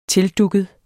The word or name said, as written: tildugget